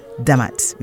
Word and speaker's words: Demat: There was once a kingdom called Demat, which is spelled D'MT, which is located in modern-day northern Ethiopia / Eritrea.